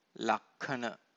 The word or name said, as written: lakkhana